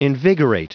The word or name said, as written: invigorate